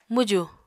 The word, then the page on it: mwjù: Apatani